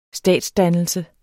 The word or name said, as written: statsdannelse